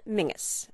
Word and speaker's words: Menzies: Sir Mingus Campbell, a member of Parliament, spells his first name M-E-N-Z-I-E-S, but he points out that the correct Scottish pronunciation is Mingus.